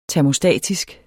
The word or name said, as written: termostatisk